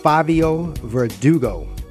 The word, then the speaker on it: Favio Verdugo: And we're back with Favio Verdugo.